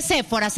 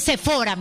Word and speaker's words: Sephora: No se dice Sephora, se dice Sephora, mijito.